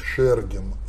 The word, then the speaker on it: Шергин: Сказ о Пушкине – это Борис Викторович Шергин. Это такое ударение, обычно Шергин называют. Шерга – это стружка северная, Шергин.